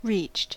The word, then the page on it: reached: American English